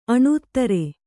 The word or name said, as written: ಅಣೂತ್ತರೆ